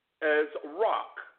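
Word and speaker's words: ezrach: Ger is in contrast with the Hebrew word as rock. Strong's Concordance H249. It is spelled E-Z as in zoo, R-A-C-H. Now, the as rock is the one who is the native or who grew up in their own land.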